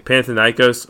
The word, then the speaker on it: Panathinaikos: That's how you say it, Panathinaikos, which is a Greek football club.